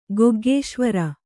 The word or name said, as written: ಗೊಗ್ಗೇಶ್ವರ